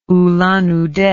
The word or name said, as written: ulan ude